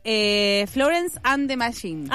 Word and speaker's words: Florence and the Machine: Taylor Swift con Florence and the Machine. – Ajá, muy bien pronunciado.